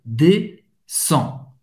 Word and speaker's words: descends: C'est não vai pronunciar nem D nem au S. Je descends. Sans. Et N t'es au son de en.